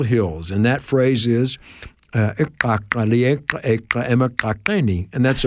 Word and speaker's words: iqaqa: The word for skunk is spelled I-Q-A-Q-A and pronounced Ikaka. It's one of the click languages. So Say that again. I can hear that click. – Right. It is Ikaka.